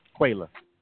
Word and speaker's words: Quayla: I actually called because you've been pronouncing my name wrong for so long that I had to call you and say, look, my name is Quayla, Shaquayla, okay? That's how Quayla. – You pronounce it. – Quayla.